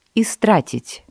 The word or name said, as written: истратить